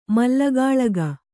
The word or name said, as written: ಮಲ್ಲಗಾಳೆಗ